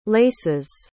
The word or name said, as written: laces